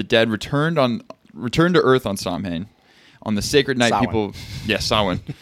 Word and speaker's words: Samhain: They have a festival called, it's called Samhain, but it's not spelled like that at all. S-A-M-H-A. – Samhain. – Samhain, basically, is out, but it's Samhain, yeah.